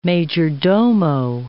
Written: majordomo

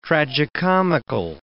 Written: tragicomical

